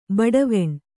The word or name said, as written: ಬಡಾವಣೆ